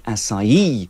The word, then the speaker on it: açaí: This is in Brazilian Portuguese. It's something like açaí, with the main stress on the E. And we know it's pronounced as a S sound because underneath the letter C, there's a cedilla. It's a little comma.